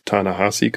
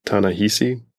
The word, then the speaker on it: Ta-Nehisi: Listened to an NPR podcast with an interview with who I now know is Ta-Nehisi Coates and not the way I've been pronouncing it throughout our previous podcasts. – Which was? – Ta-Nehisi. Because it's spelled I-S-I, but that's not how you say it.